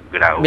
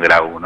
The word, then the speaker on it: Grau: ¿Gabriel Gro o Grau? – Grau. – Bien. – Sí, hay mucha gente, la A y la U, la pronuncia Gro, pero bueno, por lo menos cuando llegó el apellido por acá, llegó como por lo menos pronunciado en Grau, ¿no? – Bien.